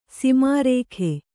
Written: ಸೋಮರಾಗ

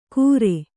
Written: ಕುರೆ

